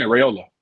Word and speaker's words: Raiola: Yeah, Raiola.